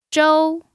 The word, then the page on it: zhou: 1